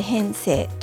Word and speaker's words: 変性: えっと、帯状っていうのは帯の状態の状ですね、えっと、帯状疱疹とかと同じ体状でで角膜、えっと、変性っていうのは変わる性質と書いて、え変性と読みますけれども、